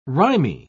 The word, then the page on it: rimy: British English